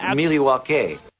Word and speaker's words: Milwaukee: Like, look, Giannis may not be in Milwaukee after next year. – Actually, it's pronounced Milwaukee.